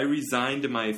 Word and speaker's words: resigned: Z 발음으로 하면은 사직한다 이런 의미고 S 발음으로 하면은 이렇게 그 이거 재계약. So he re-signed S 발음으로 했잖아요.